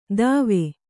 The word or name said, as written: ತವೆ